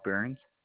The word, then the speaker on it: Burns: Luke Burns. – L-U-K-E? – Yes, ma'am. – B-U-R-N-S? – Yes, ma'am.